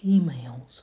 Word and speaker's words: emails: Смотрите, проверять электронку, check emails. Ударение падает на первый слог. Yeah, the first syllable is stressed.